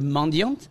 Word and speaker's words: mendiant: So, mendiant, just so you know, the French word mendiant is actually pronounced mendiant. We don't pronounce the T at the end.